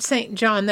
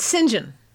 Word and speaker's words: St. John: Now, this book, Shalom Means Peace, was written by a British uh, author by the name of Robert St. John. Now, those of you who are familiar with British nomenclature and are purists will rush up and say, Oh, no, no, that's not St. John, that's St. John.